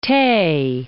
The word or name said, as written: Tay